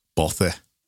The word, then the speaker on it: bothy: How to pronounce? – Bothy. – Bothy. – Bothy. – Bothy, apparently.